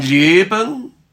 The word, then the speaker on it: Leben: Und mein Leben, whenever you've got the E in a second syllable, un, not Ben, make sure it's not Ben.